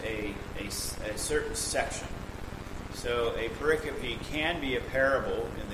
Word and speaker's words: pericope: It's not pericope or even periscope. It's pericope, is how you pronounce the term.